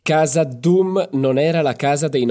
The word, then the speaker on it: Khazad-dûm: By the way, in Italian, you shouldn't say Hazad Doom, as it should be in the Elvish pronunciation, because in Italian, I don't know why, they don't really use those sounds. – Interesting. – So you should just say Hazad Doom.